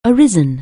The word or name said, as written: arise